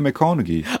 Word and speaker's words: McConaughey: Matthew McConaughey.